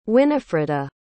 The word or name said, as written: Winifreda